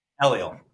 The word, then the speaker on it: ELIEL: ELIEL.